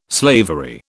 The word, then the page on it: slavery: British English